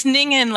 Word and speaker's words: Ningen: It's Ningen, like singing in the rain.